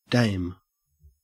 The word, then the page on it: dame: Australian English